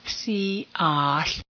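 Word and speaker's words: Si'ahl: So Si'at was Duwamish Suquamish, and his name has been misspelled and mispronounced to be something other than Si'at. Si'at.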